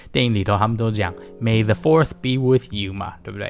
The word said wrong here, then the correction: Force: 对？Actually，其实应该是刚刚发音不太准，应该是 May the Force be with you，然后呢，谐音就有点像 May the Fourth，所以就是五月四号就变成星际大战日啦，啊。